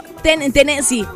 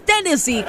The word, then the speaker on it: Tennessee: Yo he dicho Tennessee. Y pues no es así. Es Tennessee, con acento en la primera E.